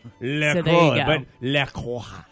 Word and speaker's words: La Croix: In France, it is said as La Croix.